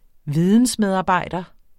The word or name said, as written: vidensmedarbejder